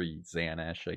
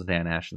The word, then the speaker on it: Xanesh: It's this Dark Inquisitor Xanash, I guess. Like it ends with S-H. I don't know how you'd like actually pronounce it. It's good enough. X-A-N-E-S-H, Dark Inquisitor Yanash.